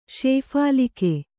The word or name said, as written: ಶೇಫಾಲಿಕೆ